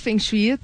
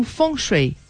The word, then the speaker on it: Feng Shui: É, bom, pode falar Feng Shui, tá? Não tem problema. A pronúncia é mais parecida na China é algo como Feng Shui feng shui.